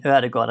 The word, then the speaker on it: Ødegaard: Martin Ødegaard, that's how I pronounce it in Norwegian.